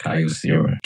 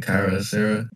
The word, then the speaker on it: Kyocera: I think it's pronounced Kyocera. K-Y.